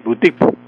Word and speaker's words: boutique: Entonces, esa palabra boutique pasó al español tal como se pronuncia en francés, boutique.